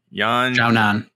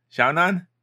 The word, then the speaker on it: Yan Xiaonan: I, how do we say her name again? Yan Nan, Yan Xiaonan. Nan, Yan Nan. Yeah. Ooh, how do you say it? Esther Lin with the guest appearance. Xiaonan. – Xiaonan.